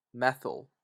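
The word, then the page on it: methyl: Canadian English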